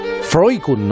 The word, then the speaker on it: Freuchen: Eh, mira, fíjate, me estás hablando de Freukun, ¿no? – Se pronuncia así.